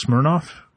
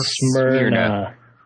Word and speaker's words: Smyrna: Tell us how awesome it is to live in Smirnoff or Smyrna? – Smyrna. – S-M-Y till I die. That's what the locals say.